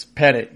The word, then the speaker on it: Pettit: Is it Petit or Petit? It's Pettit, right? Is that how he pronounces it? – It's Pettit, yes.